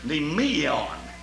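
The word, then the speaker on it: nemeion (μνημεῖον): and that's the word nemeion. Now, that's a mouthful, but that's spelled M-N-E-M-E-I-O-N, and that means the grave.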